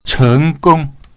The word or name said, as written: cheng2gong1